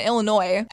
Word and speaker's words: Illinois: Illinois. Added a little S on there, the silent S.